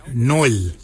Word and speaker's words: Nyooly: A-R-L-Y, Nail, for underarm. For your elbow, we say Noil. Noil is spelled N-Y-O-O-L-Y, Noil.